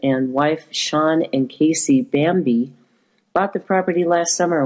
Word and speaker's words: Bambei: Sean Bambei, pronounced like the Disney movie, it's uh, spelled B A M B E I,